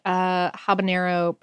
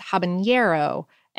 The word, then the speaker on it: habanero: Also, you might have heard habanero pronounced habanero, and and that's, yeah, like a malapropism? No, it's something similar to that. It's just a phoning mix-up from words like jalapeno, which does have the ñ.